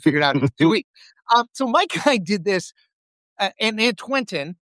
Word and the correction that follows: Antwentig: did I say that right? – Antwentig. It's the Old English Antwentig. For 21. Antwentig, yes.